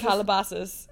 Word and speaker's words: Calabasas: Calabasas.